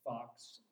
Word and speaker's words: Fox: And I said, "Fox, F-O-X."